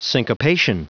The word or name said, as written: syncopation